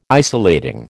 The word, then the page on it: isolating: American English